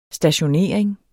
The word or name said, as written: stationering